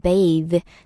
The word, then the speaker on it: bathe: 네, 목욕시키다라는 표현이 bathe, b-a-t-h-e 발음을 조금 주의하셔야 되는 게 그냥 목욕 bath 하고 발음이 많이 달라져요. 동사로 쓸 때는.